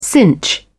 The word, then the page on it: cinch: British English